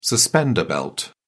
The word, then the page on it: suspender belt: British English